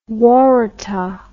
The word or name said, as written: waratah